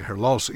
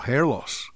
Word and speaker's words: Her Loss: Her Loss se estrenó el 4 de noviembre, por cierto, en inglés se pronuncia Her Loss y no Hair Loss, que en español sería algo así como pérdida del cabello.